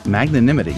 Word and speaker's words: magnanimity: Magnanimity. You did pretty good, I would say.